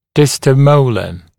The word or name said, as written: distomolar